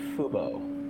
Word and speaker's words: Fubo: Did I say it wrong at the end? – Yeah, I heard FUBU just now. But you might have FUBU'd it. – FUBU. It's FOBO. FUBO. FOBO. It's FUBO.